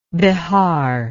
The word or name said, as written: behar